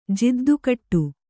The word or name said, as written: ಜಿದ್ದುಕಟ್ಟು